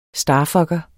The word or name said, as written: starfucker